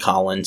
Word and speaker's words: Colin: My name is spelled Colin, C O L I N, no matter what you're saying about me.